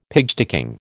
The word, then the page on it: pigsticking: American English